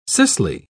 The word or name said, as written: cicely